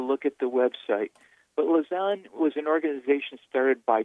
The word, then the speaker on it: Lausanne: Lausanne.